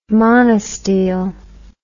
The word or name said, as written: monostele